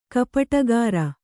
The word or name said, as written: ಕಪಟಗಾರ